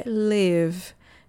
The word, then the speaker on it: live: A pronúncia é live e não é live. Se escreve L I V e, mas se pronuncia live, não se pronuncia live, porque é um verbo, o verbo viver, o verbo morar.